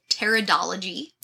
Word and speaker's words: pteridology: The study of ferns is called pteridology. – That one I did know. – That's with a silent P. – Kind of like pterodactyl.